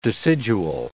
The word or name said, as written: decidual